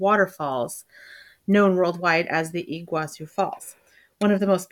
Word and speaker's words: Iguazu: Is it Iguazu? I don't know how to pronounce that. Do you know? – Iguazu. – Okay. Iguazu. – Yeah. – Iguazu. I don't know. There you go.